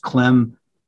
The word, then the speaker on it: Klem: It's just Clem, K-L-E-M, at Muhlenberg.